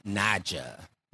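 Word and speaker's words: Naija: So our next song is called Pack Light by Queen Naya. I believe that's how you say her name. – Queen Naja. – Naja. Okay. Naja.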